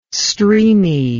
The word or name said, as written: streamy